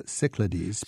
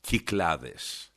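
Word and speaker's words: Kyklades (Κυκλάδες): This is the island Syros, S-Y-R-O-S. – Exactly. – And I always pronounce this sort of the anglicized way, Cyclades. – Cyclades. – But the correct Greek pronunciation is what again? – Kyklades.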